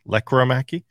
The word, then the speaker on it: Lekkerimäki: Also, E&O for us both, or for the entire market, we're finding out Lekkerimäki, not Lekkerimäki. Accent on the first syllable now is what it seems to be. Lekkerimäki.